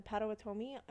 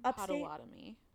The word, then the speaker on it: Potawatomi: I think you mean Potawatomi. – Potawatomi? – Yeah, I think so. Okay, yeah. That's... Obviously, I said... I knew I was saying it wrong.